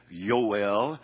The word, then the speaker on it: Yoel: The reason I'm trying to pronounce his name, as some of you are kidding me about this, is because that A is the way you would pronounce it in the Hebrew. B, because the point of his name is Yahweh. Really, I should be saying Yoel, but Yahweh is God.